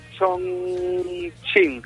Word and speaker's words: Chongqing: ¿Se dice así, Chongqing? – Chongqing, sí. – Chongqing. – Chongqing Lifan. – Se escribe sí. Chongqing, pero se lee Chongqing.